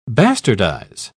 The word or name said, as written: bastardize